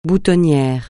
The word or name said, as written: boutonnière